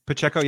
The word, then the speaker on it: Pacheco: Last one I like, Isaiah Pacheco. I think I'm saying his name right. Am I saying that right? – Pacheco, yeah.